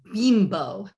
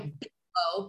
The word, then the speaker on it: Bimbo: and it's a big goods company called Grupo Bimbo. It's spelled like Bimbo, but it's pronounced Bimbo.